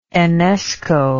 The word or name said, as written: Enesco